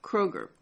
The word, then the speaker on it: Kroeger: Beresford Kroeger. Kroeger. K-R-O-E-G-E-R.